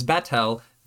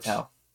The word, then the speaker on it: Batel: Betal? Betel? – We should listen to how they say her name. – Betel, B-A-T-E-L. She hands Catul hands Betel the bookmark. – Betel. – Yeah, Betel.